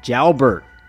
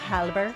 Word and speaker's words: Jalbert: I said Jailbait last time. Jalbert on drums. How would you pronounce that? That last one there. – Where it says... Jailbait? Just kidding. Halbert. – Well, the J is silent.